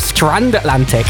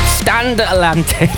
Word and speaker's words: Stand Atlantic: I might have mispronounced this band earlier. I think I said Strand Atlantic. Stand Atlantic.